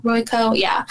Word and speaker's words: Royko: He is the son of columnist Mike Royko. Am I saying that right? Mike Royko? Royko? Yeah.